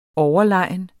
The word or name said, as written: overlegen